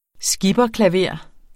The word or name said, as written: skipperklaver